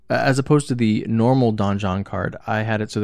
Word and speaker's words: Donjon: No, it's pronounced Donjon. – You think so? – It is, yeah. Because a donjon is not a dungeon. It's like the inner keep. I believe it's pronounced Don Juan.